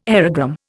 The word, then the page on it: aerogramme: Indian